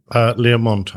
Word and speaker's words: Learmonth: Is that Learmonth? Is that the way you pronounce that? – Learmonth, I think.